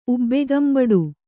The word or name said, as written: ಉಬ್ಬೆಗಂಬಡು